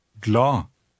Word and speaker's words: glad: The D at the end is silent. Try it. – Glad.